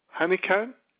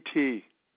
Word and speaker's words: Honeycutt: Bill Honeycutt, HoneyCutt, two T's.